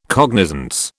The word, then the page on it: cognizance: British English